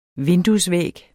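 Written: vinduesvæg

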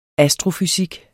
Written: astrofysik

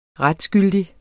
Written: retsgyldig

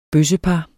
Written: bøssepar

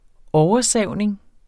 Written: oversavning

